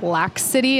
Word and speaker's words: Lax City: I think his name is pronounced Lax City. It's L-A-X City. – Oh, yeah. Nice. – But he's not from L.A., like L-A-X City. Just Lax City, I guess.